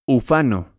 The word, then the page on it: ufano: Spanish